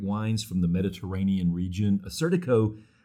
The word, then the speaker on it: Assyrtiko: That's spelled Assyrtiko. Some people may pronounce it as Assyrtiko, but I've most often heard it in the United States pronounced as Assyrtiko.